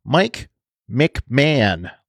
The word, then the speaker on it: McMahon: But for some reason, I kept saying Mike Mahan, and that I knew that was wrong. It's Mike McMahon, like Ed McMahon, like from The Tonight Show.